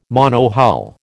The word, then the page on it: monohull: American English